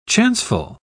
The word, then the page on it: chanceful: British English